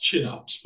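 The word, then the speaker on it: Kinnarps: There's a company called, it's pronounced Chinups, but it's spelled Kinarps, K-I-N-A-R-P-S.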